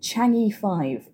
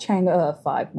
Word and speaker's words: Chang'e: This was the Chinese space agency's third trip to the moon, with Chang'e 5, it was called. Actually, I believe it's pronounced Chang'e 5, my apologies.